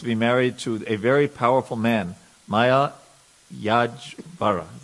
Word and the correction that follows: Malayadvaja: Did I pronounce that right? Malayadvaja. Malayadvaja. Thank you.